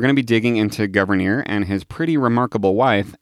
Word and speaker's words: Gouverneur: Yeah, last week I pronounced it Gouverneur. – Gouverneur? – But historians are pretty sure it's Gouverneur.